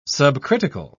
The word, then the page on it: subcritical: British English